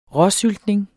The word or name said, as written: råsyltning